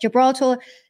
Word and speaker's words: Gibraltar: I want to pronounce words the way that they're they're written, but Gibraltar, Gibraltar, which was a UK territory, they had very high vaccination rates.